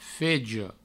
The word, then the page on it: fege: Provençal